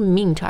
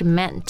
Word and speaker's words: meant: 后面加一个 t 但它的发音不是 meant 而是 meant meant right so it's like went he went to the store